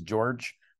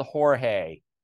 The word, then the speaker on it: Jorge: I like being called Jorge.